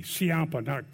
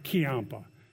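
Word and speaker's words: Ciampa: Siampa, by the way, I got to apologize. Adam told me that's a soft C. Siampa, not Kiampa.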